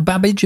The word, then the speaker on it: Babbage: Do you know anything about Babbage apart from his name rhyming with cabbage?